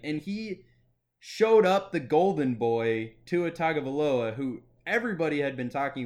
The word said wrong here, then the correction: Tagovailoa: Tua, you're missing a syllable in his name. What? – Tua Tagovailoa. You're missing a syllable. You're putting one too few syllables in his last name.